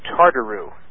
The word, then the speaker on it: tartaroo: That's the word Tartaro. It's spelled T-A-R-T-A-R-O-O.